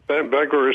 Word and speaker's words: Bangor: Well, Bangor is the right pronunciation, but...